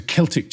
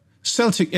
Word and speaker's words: Celtic: Google obviously doesn't know the difference between a hard C and a soft C. – Oh, of course. – That's, I suspect, is a Celtic cheese board. – Yes. – However, Celtic FC, football fan, here we go.